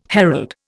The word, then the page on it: herald: Indian